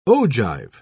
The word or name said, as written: ogive